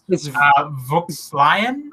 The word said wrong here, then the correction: Vieux Lyon: It's Vieux Lyon, yeah. It's Vieux Lyon, which is like old Lyon. Vieux is old.